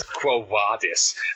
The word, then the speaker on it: Quo Vadis: I gave you the ecclesiastical pronunciation as Quo Vadis, as the classical pronunciation is.